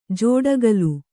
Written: ಜೋಡಗಲ್

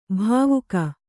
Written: ಭಾವುಕ

